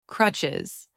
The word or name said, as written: crutches